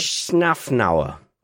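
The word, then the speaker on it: Szafnauer: I was laughing at your pronunciation of Otmar's surname. It's all good. – Schaffnauer. – It's quite, yep, perfect.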